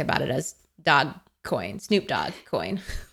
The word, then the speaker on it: Dogecoin: You know, I'd go somewhere so that no one tried to kill me for my Dogecoin, which I just Googled, and it is pronounced Dogecoin for anyone out there. But Nicole and I forever call it Dogecoin.